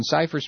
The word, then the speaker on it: Cypress: When you're not in Paris, you're in Cypress Springs? – Yeah. – How do you spell that, for God's sake? – C-Y-P-R-E-S-S.